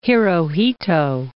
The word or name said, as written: Hirohito